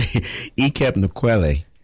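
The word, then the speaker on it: Ekep Nkwelle: And the pronunciation on the... – Oh, it's, you can put it to me. Ikep Nkwele.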